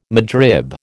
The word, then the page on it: midrib: American English